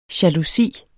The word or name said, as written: jalousi